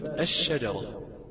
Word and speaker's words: الشجرة: أما اللام هي ساكنة ولكن هي سكنت في حال إدغامها في الشين فبقت الشجرة الشجرة بالضبط.